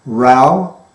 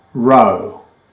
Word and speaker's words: row: Also, row, different pronunciation, row, row. A quarrel, a loud argument, a loud noise. To row, verb, to move a boat using oars.